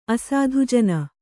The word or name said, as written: ಅಸಾಧುಜನ